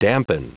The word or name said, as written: dampen